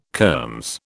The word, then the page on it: kermes: British English